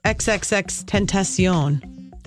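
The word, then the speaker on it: XXXTentacion: Boy, this shooting death of this rapper, XXXTencion.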